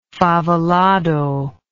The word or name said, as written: favelado